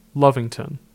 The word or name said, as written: Lovington